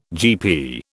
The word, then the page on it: GP: British English